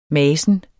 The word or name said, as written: masen